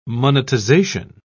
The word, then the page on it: monetization: British English